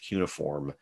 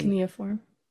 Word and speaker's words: cuneiform: So like I think it's pronounced cuneiform. – Yep. – So like hieroglyphics and cuneiform.